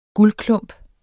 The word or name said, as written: guldklump